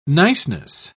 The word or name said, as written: niceness